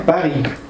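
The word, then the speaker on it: Paris: А потому что пари пишется с буквой «С» на конце, а буква «С» на конце не читается. – А, если за ней нет. – Если за ней нет согласных. Ой, гласных. Если за ней нет гласных. Поэтому читаем «пари».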